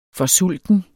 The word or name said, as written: FORSULTEN